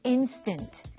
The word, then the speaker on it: instant: So the first word is instant. Instant. 네, 한국 분들이 인스턴트라고 이렇게 발음을 하죠. 원어민들은 인스턴트라고 합니다.